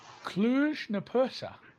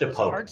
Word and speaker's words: Cluj-Napoca: Cluj-Napoca. – I think it's Napoca, but I think you're pretty close. I think it is a hard C.